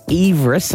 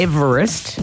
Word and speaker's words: Everest: Did you know that Sir George Everest, as in what we've been calling Everest, he actually pronounced his name Everest, so heavy on the E-E-V, not Everest, and objected to the mountain being named after him.